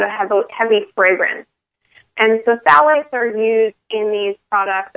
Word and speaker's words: phthalates: and those are called phthalates. And it's spelled with a P-H-T-H, which throws a lot of people because it's just pronounced with a T-H.